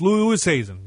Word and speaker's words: Oosthuizen: It's Louis Oosthuizen.